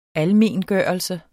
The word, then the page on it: almengørelse: ˈalˌmeˀnˌgɶˀʌlsə; ˈalˌmeːn-